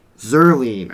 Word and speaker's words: Zuerlein: Next up will be Greg the Leg Zuerlein. I think that's how you pronounce his last name.